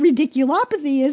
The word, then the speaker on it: radiculopathy: And you have to tell us what ridiculopathy is, and even if I pronounced it correctly. Yes. – You did. You did.